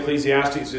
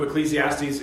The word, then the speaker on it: Ecclesiastes: And so the English title then, Ecclesiastes, is just an Anglicanized pronunciation of that Greek title, translating the Hebrew word meaning assembly, or one who gathers together an assembly.